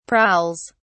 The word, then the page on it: prowls: British English